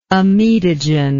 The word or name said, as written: amidogen